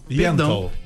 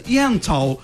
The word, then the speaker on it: Yentl: Ó, a querida Alessandra Maestrini, ela tá em peça, ela tem peça, que é Ente. Ent in Concert. – Yentl. – Eu vou fazer com pronúncia Yentl in Concert.